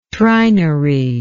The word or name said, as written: trinary